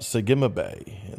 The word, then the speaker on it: Segimabe: I'm your host, better known as Segimabe. That's my Hebrew name, Shemat Gog Gimel Ayin Bet Yod. And it sounds out Segimabe. And the Yod is silent, the D is silent,